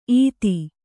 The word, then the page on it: ಈತಿ: Kannada